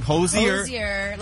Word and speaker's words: Hozier: Hozier. – Like you want to hose off Hozier.